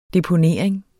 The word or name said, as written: deponering